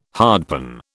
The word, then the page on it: hardpan: British English